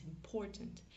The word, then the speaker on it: important: Pour, parce que l'accent tonique est sur cette syllabe-là, sur la syllabe du milieu. It's important.